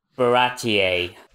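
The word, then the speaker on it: Baratie: And, oh yeah, this is when Garp and his men arrive at Baratier.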